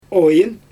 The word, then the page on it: ojIn: Xyzyl